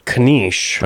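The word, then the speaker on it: knish: So let's say 3 for that and 5 for the solar eclipse, and we'll just put 1 point on Kanish, kind of spelled like niece with a K at the beginning and an H near the end.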